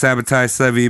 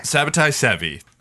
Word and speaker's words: Sabbatai Zevi: So this guy, I think it's pronounced Sabbatai Zevi, but I also heard people go like, Sabbatai.